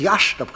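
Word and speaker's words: Iași: Les jésuites, venant de Pologne, dans leur collège de Yassi, qui se prononce Yach,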